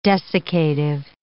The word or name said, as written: desiccative